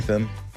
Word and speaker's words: Statham: How do you pronounce his last name? – Statham. – Statham. Statham. – Yep.